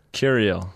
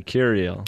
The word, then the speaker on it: Kirill: How do you say it? – Kirill. – Like Kirill. – Cereal with a K. Kirill.